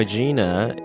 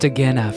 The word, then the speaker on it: Agena: You can download the Dead Pilot Society podcast and look for Birthright, which is my pilot, where Keiko Agena is reading that. – It's pronounced Agena for the next Agena.